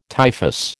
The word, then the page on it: typhus: American English